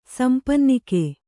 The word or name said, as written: ಸಂಪನ್ನಿಕೆ